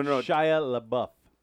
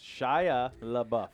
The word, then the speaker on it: Shia LaBeouf: Shia LaBeouf. – Shia LaBeouf. – Yes, Shia LaBeouf.